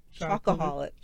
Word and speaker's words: chocoholic: The other comment we had on that video comes from another chocolate holic. So the question... – He doesn't even know how to say it right. Chocoholic.